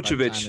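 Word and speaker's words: Vučević: Oh, it's Vucevic.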